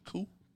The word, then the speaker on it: coup: Coup.